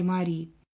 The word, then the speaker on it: Мари: Мари, ее имя почему-то произносили именно так, без последнего гласного и с сильным ударением на последнем слоге.